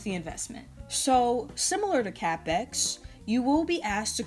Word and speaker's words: Cappex: So, again, to recap, CAPPEX is spelled C A P P E X dot com.